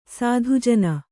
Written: ಸಾಧುಜನ

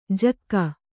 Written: ಜಕ್ಕ

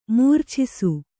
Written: ಮೂರ್ಛಿಸು

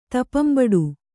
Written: ತಪಂಬಡು

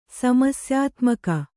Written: ಸಮಸ್ಯಾತ್ಮಕ

